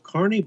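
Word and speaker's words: Kearny: It's probably pronounced Kearney, but we don't get too upset about it.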